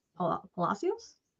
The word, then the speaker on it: Palacios: Palacios? – Palacios.